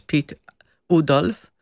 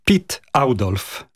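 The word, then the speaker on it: Oudolf: Ja myślę, że dla nas po polsku najłatwiej jest powiedzieć Pit Udolf, ale to nie jest poprawne i mi to zajęło trochę czasu, żeby się przyzwyczaić, jak to się naprawdę wymawia. Jego pierwsze imię to jest Pit, a nazwisko Audolf. To dla nas nie jest takie naturalne przeciągnąć to, ale tak się poprawnie wymawia po holendersku. – Czyli Pit Audolf.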